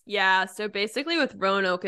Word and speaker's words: Roanoke: Roanoke. Am I pronouncing that right? – Mm-hmm. Roanoke? Yeah, Roanoke.